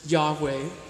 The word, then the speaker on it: Yahweh: It's translated in the Hebrew Old Testament, all consonants, no vowels. The way it's pronounced is Yahweh.